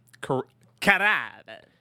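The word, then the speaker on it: Carrabba's: Carrabba.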